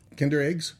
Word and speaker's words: Kinder: Kinder eggs? – Yes, that's what the other ones are called.